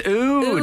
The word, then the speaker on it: old: Because you're so poetry, I thought you were saying O-D-E, ode. – No, ode to wood. – In the black country, it's how you'd say old, where I come from. – Oh, so yeah. – Say, smells of old wood in here. it's ode. – Ode, it's pronounced.